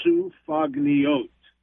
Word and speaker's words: sufganiyot: Okay, I'm going to say it again there's no silent letters. Sufagniot.